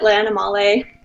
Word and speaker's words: L'Animale: Yeah, I pronounce it L'Animale.